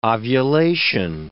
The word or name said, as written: ovulation